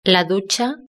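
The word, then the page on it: la ducha: Spanish (Spain)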